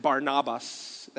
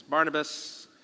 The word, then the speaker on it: Barnabas: After some time had passed, Paul said to Barnabas, or some people made fun of me because I said Barnabas. That's actually how you say it, but anyways, Barnabas.